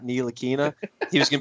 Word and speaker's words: Ntilikina: Ntilikina. – Ntilikina.